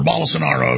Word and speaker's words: Bolsonaro: And that's where I'm going next with Bolsonaro, or Bolsonaro, as you pronounce it correctly.